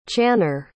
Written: channer

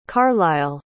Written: carlyle

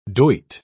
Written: doit

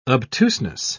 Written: obtuseness

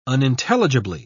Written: unintelligibly

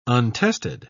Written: untested

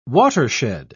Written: watershed

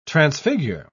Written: transfigure

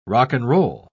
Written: rock-and-roll

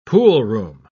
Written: poolroom

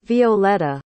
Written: violeta

